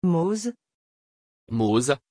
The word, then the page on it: Mose: French